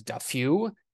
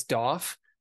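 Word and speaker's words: Daffue: Aaron Rye, head cover machine at 8,900, all the way down to MJ Doff at, or some people on like the World Tour pronounce him as Duffew. It's Doff.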